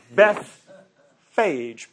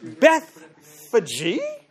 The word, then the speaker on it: Bethphage: He pronounced the name of the town that's up there on the Mount of Olives, Bethphage. It's spelled B-E-T-H, Beth, P-H-A-G-E. Now that sounds like phage to me, Bethphage, but he pronounced it Bethphage.